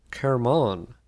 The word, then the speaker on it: Hermon: Hermon, or pronounced Kermon, means a sanctuary.